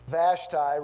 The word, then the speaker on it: Vashti: Vashti, class, V-A-S-H-T-I. Vashti. Whatever. You call it whatever you want. It is in English, and so I think I have the right to pronounce English the way American people do. You can pronounce it any way you like.